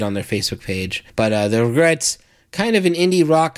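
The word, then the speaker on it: The Regrettes: And then the next act we're going to play is called The Regrets. And that's spelled R-E-G-R-E-T-T-E-S, like regrets.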